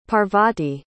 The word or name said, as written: parvati